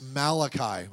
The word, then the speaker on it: Malachi: If you're Italian, you could pronounce it Malachi, whatever. But it's Malachi, okay?